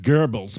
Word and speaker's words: Goebbels: For one, I think it's pronounced Goebbels, but...